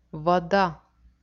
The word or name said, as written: voda